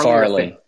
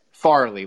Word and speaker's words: Farley: Far- how do you say his name? – Farley. Farley. Farley like Chris just think, Farley. Just, just think Chris Farley.